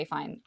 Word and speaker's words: Fiennes: Fiennes Fiennes right? You say fine.